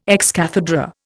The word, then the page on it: ex cathedra: Indian